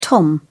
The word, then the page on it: tom: British English